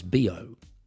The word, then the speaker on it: bio: Now, here in Germany, the word for organic is bio. It's actually spelled bio, B-I-O, but it's pronounced like the short form for the expression body odor,